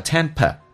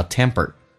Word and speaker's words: temper: 下面一个是 temper 这个单词，英式发音叫 temper，美式发音 p 浊化成 b，还有卷舌叫 temper，所以你要能听懂。